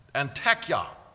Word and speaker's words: Antakya: I had to look that up on how to pronounce that. Antakya.